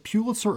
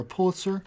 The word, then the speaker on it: Pulitzer: And by the way, is it Pulitzer or Pulitzer? – Well, I was told by somebody who knew the family in St. Louis, and they said the family pronounced it Pulitzer with a long U. But I noticed most magazine and radio newspaper people say Pulitzer.